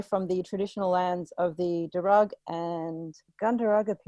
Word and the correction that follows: Gundungurra: people. Have I pronounced that correctly? – Gundungurra. – Gundungurra, okay.